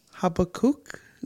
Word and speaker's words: Habakkuk: I think it's Habakkuk. Is that how you say it? – Yeah.